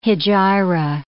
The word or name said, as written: hejira